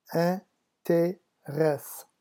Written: interesse

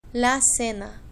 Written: la cena

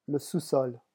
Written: le sous-sol

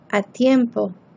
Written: a tiempo

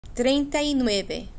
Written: trienta y nueve

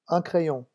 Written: un crayon

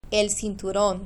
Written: el cinturon

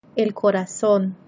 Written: el corazon

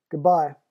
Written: good-bye

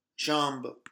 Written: jambe

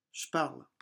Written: je parle

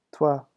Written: toi

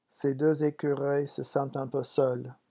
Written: ces deux

